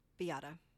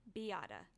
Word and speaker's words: Beata: And then mother's name is, tell me if I pronounce this correct, Beata. – Beata. – Beata. I cannot tell you how long I had this as Miata until about halfway through. And I said, I think he's saying a V.